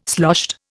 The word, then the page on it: sloshed: Indian